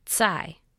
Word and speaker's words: Tsai: Tsai, I correct. I hear my dad in the patient voice he uses to explain the pronunciation of our surname. Tsai, like itsy, bitsy, spider.